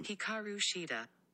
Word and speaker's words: Hikaru Shida: Hikaru Shida.